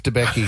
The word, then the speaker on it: Debicki: Elizabeth Debicki. – Is that how I say her name? – Yeah.